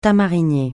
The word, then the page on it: tamarinier: francais